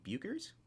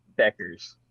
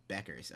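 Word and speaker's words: Bueckers: And then Paige, I don't know how to say her last name, Bukers? – Beckers. – Beckers, okay, yeah.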